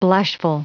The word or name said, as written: blushful